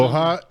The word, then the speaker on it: Lohja: Loha or something? – Loha, L-O-H-J-A. – I'm going with Loha.